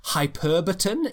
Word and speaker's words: hyperbaton: Not only an antithesis, but it's what's called hyperbaton is how I would pronounce it. Hyperbaton sounds like a light, Hyperbaton. – yeah, sounds like a lightsaber if it's a hyperbaton.